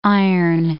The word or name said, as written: Iron Gate